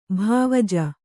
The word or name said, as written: ಭಾವಜ